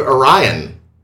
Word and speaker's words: Orion: The Pirates of Orion. – Hey, you pronounced Orion right. – I had to work at it.